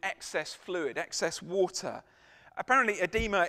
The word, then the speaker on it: edema: Most likely this man was suffering from edema. Now, I'm aware I'm speaking to a lot of medics here. Am I pronouncing that correctly? Edema? Yes. Got it right.